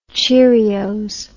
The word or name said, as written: cheerios